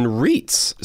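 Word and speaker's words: REIT: You can do that by investing in REITs. So that's how you say it, but it stands for Real Estate Investment Trusts.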